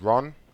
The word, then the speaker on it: Ron: My first name is Ron. – How do you spell it? – It's quite tricky. It's R-O-N.